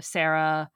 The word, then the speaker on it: Sarah: So it's like a totally, different way of saying you've got to roll the R in the Sarah. And it's Hispanics. Yeah. Hispanics tend to, they pronounce it right. The Sarah part usually.